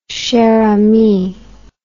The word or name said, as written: cher ami